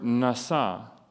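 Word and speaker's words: nasa (נשא): the word nasa.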